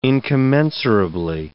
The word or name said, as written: incommensurably